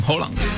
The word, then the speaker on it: Haaland: En Inglaterra dicen normal, debería ser Haaland, pero sí. Dicen es Haaland, pero en noruego la doble A suena como una O. – Haaland. – Haaland. – Erling Haaland.